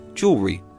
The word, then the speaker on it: jewelry: If you want to say it with an American accent, jewelry, two syllables, and spelled J-E-W-E-L-R-Y. And if you want to use the British spelling and pronunciation, then now you have three syllables. Jewelry, J-E-W-E-L-L-E-R-Y, jewelry.